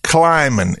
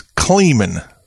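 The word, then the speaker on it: Klieman: It ain't Kleiman. It's Kleiman.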